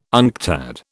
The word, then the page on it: UNCTAD: British English